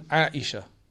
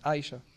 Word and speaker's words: Aisha: Second one, Aisha. Again, very difficult because you have to say the ayn.